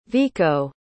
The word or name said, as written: vico